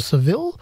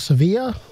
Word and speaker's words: Sevilla: but that's 90 kilometers to the east from Seville, or Sevilla, if you want to use the Spanish pronunciation.